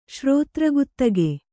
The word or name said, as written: ಶ್ರೋತ್ರಗುತ್ತಗೆ